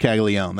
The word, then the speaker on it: Caglianone: Yeah, I think it's Caglianone, I think is what it should be. – I had issues, but it is Caglione. That's just how everybody pronounces it.